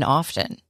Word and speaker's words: often: He said, and I didn't believe him at the time, but he said, you don't pronounce the T in often. It's just often. He said the T is silent.